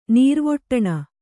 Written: ನೀರ್ವೊಟ್ಟಣ

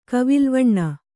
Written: ಕವಿಲ್ವಣ್ಣ

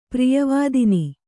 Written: ಪ್ರಿಯವಾದಿನಿ

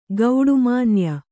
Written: ಗೌಡುಮಾನ್ಯ

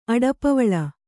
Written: ಅಡಪವಳ

